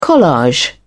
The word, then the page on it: collage: British English